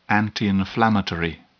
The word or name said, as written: antiinflammatory